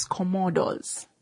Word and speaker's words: Commodores: It's Commodores.